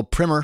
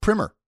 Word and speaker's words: primer: Our own Henry Pickavet, our executive producer here on Equity, uh, told me that it's pronounced primer, not primer. I called BS on that and Googled it, and uh, he's right primer is the first coat of paint you put on a house, for example, and a primer is an overview or a guide to a subject.